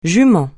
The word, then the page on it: jument: francais